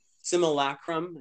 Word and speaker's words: simulacrum: And in that sense, they've kind of locked us in that simulacrum. Is that right? Did I say it right? – Yeah. Yep.